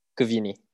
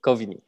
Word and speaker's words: Kovini: Is it Kovini? – Kovini. – Kovini.